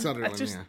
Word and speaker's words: Sutherland: Yeah, it's Sutherland, Okay. It's kind of like Sutherland from like Keither Sutherland. – Yeah. – But yeah, you just put Sutherland.